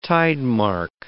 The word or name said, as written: tidemark